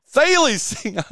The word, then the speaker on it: Thales: Is it how do you pronounce Thales? – Thales. – That's what we joked about yesterday on the show. I was like, I bet you I'm pronouncing that wrong. And sure enough, I was.